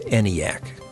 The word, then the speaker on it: ENIAC: In 1946, the largest and most sophisticated electronic computing device was the ENIAC, which the designers pronounced ENIAC at the University of Pennsylvania.